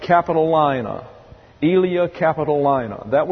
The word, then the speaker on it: Capitolina: It's A-E-L-I-A, pronounced Aelia, and then Capitolina, C-A-P, I think it's O rather than A, C-A-P-I-T-O-L-I-N-A, Capitolina.